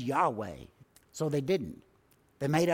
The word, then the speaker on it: Yahweh: Yahweh, that we hear a lot today, really comes from four letters of the Hebrew language. Yod, He, Wah, He. Yahweh is how we pronounce it.